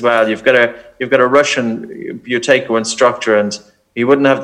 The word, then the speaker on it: Buteyko: And that's spelled B-U-T-E-Y-K-O, isn't it? – Yeah, correct. Yeah, Buteco cool.